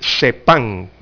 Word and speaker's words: CEPPAN: Así que son 40 millones de dólares aproximadamente y, eh, bueno, habrá una reglamentación entonces para hacer efectivo este CEPAN, que se escribe C-E-P-P-A-N, CEPAN con doble P.